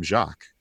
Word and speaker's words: Jacques: We've been calling him Jacques because it sounds lovely in French, but how are you pronouncing his name? – It's Jacques.